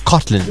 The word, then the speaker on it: Cotlands: Do I say Cotlands? Cotlands. – Yes.